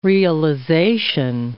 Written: realization

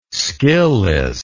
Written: skillless

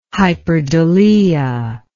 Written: hyperdulia

